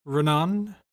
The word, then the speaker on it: Ronan: Ronan? I think you got to say it with an Irish accent. Ronan.